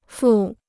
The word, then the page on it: 付: fù